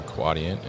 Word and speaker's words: Quadient: They just rebranded from Neopost, and now they are Quadient, and we were discussing how to pronounce it correctly. Yeah. So it's Quadient, right? – Yes.